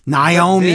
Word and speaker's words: Naomi: Cole constantly in this match has to correct football head on how to pronounce Naomi's name. Because football head will say, Naomi getting the kicks in. And Cole says, yes, Naomi is doing this. Naomi.